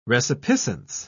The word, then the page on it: resipiscence: British English